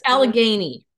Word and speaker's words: Allegheny: Did I say that right? Alleghenies? – You got me. – Mountains. The Alleghenies? – Allegheny. Allegheny. – Alleghenies. Okay. I was close.